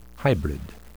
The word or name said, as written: hybrid